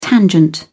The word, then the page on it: tangent: British English